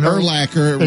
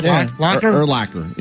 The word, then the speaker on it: Urlacher: He's still doing Urlacher rhymes with linebacker. Right. It's real simple. – He's still doing that. – Urlacher.